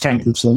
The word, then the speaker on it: Tankersley: It's just Tankersley.